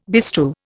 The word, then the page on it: bestrew: Indian